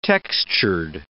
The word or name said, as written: textured